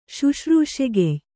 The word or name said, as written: ಶುಶ್ರೂಷೆಗೆಯ್